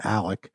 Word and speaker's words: Alex: It's Alec Murdaugh is his name. That's not how he spells it. He spells it A-L-E-X, but everyone calls him Alec.